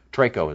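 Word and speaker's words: Treko (τρέχω): Treko, I think, is the Greek word. Treko is a better pronunciation.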